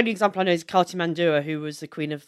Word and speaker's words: Cartimandua: Cartimandua.